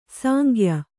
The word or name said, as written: ಸಾಂಗ್ಯ